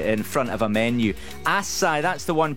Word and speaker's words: acai: Well, apparently, acai, as in the berries, you know, the healthy stuff, A-C-A-I, that's how you say it, supposedly.